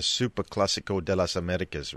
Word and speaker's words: Super Clásico de las Américas: The Super Clásico de las Américas, right? It has a lovely ring to it. – I'm very impressed with your pronunciation, mate.